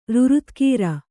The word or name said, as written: ರುರುತ್ಕೀರ